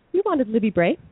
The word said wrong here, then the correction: Libba Bray: Libba Bray.